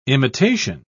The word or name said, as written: imitation